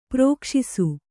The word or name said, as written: ಪ್ರಕಾಶಿಸು